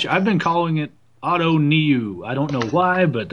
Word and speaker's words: Ottoneu: I'm part German, and the German pronunciation would be Otto Neu, like N-O-I. And I was looking at an old Eno Saris tweet that explains the pronunciation to Dave Cameron, saying the same thing. But in German, Otto Neu would be Otto Neu. So Otto N-O-I, Otto Neu, which sounds really funny off the tongue.